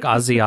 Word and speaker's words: Aussie: How did you say it was? Aussie crimes. – Like Ozzy Osbourne.